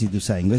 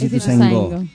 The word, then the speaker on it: Ituzaingó: No, no es Ituzaingó, es Ituzaingó. Ituzaingó. – Go, bien. – Con acento en la última O. – Porque es guaraní.